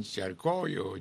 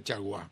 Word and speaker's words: Chargois: Aquí dicen Charcoyo, Charcó. Se escribe Chargois y se pronuncia, es un apellido francés, se pronuncia Chargois.